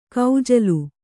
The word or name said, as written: ಕಜ್ಜಾಲಿ